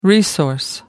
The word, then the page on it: resource: American English